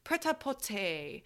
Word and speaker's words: prêt-à-porter: I looked up how to say this prêt-à-porter collection, which means ready to wear.